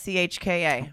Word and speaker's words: Hauschka: It's Hauschka.